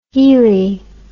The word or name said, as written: healey